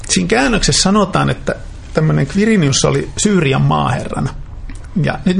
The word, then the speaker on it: Quirinius: Se on ihan niin kuin KV, Quirinius, hän äänsi itse nimensä. – Mutta miksi me sanomme Kyrenius?